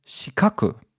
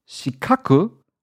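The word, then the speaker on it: shikaku (四角): But when you pronounce the word shikoku, it's very close to the Japanese word for square, for the, you know, the geometrical form of a square, which is shikaku. So shikoku, four borders, shikaku, basically four angles.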